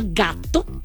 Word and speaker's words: Gatto: Se llama Gatto. Y digo gato porque está escrito como se escribe en italiano, que es con doble T. Y cuando lleva doble T, se tiene que hacer más fuerte la pronunciation, ¿ok?